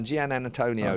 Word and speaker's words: Giannantonio: There's an extra syllable in there you don't expect. – You've got to split two words into one. Gian Antonio.